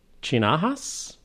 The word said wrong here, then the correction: Chianakas: That's a K. Chianakas, I would say.